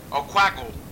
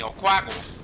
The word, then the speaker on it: Oquago: This is Mr. Oquago from Oquago Camps. – Oquago? – Oquago. Say it with me, Oquago.